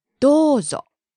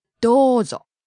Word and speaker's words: どうぞ: It has a long O vowel. どうぞ. It's not どうぞ, but it's どうぞ. – You hold the vowel.